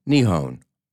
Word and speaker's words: Nihon: which is pronounced Nihon or Nippon.